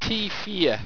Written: teifeea

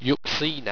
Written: yuxena

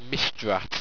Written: misdrat

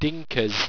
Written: dinqaz

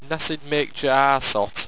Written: nasidmaquejaasot

